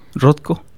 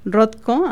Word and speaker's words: Rothko: Rothko. – Rothko, ajá.